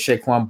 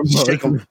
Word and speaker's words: Saquon: He don't have an H in his name, bro. – Saquon.